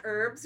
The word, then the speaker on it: herbs: If the doctor can't get to you, they're going to try to fix you up with, like, herbs and shit. – Yeah. Did you say herbs? Yeah, just herbs. You don't pronounce the H, but we got there.